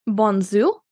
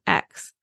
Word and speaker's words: Bonnezeaux: So you have Coteau du Lion, Bonzou, which this one looks nothing like Bonzou when you're looking at it. It's Bonnezeaux. So I'm basically learning that I definitely do not pronounce X's when I'm saying French regions.